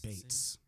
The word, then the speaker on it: Beetz: By the way, I believe she did recently correct the world on the pronunciation of her name. – Zazie Beetz. It's Beetz.